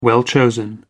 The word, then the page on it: well-chosen: American English